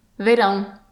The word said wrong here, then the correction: Vairão: Right, verão. And so that's a sound that's a little bit difficult to make for English speakers because we don't have it, that final vowel sound. – Actually, it'd be vairão, wouldn't it? V-A-I. Okay, vairão.